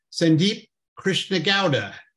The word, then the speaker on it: Sandeep Krishnegowda: This week, my Embedded Executive is Sandeep Krishnegowda.